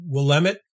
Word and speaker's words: Willamette: It's Willamette and out by Eugene, Oregon.